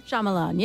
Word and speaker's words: Shyamalan: Night Shyamalan. Say that right? – Shyamalan, yes.